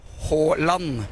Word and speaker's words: Haaland: I would like to know about Holland, because in Brazil we say Holland. – Yeah. – In Norway, you guys say Holland, right? – Holland.